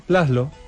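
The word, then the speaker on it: László: Laszlo.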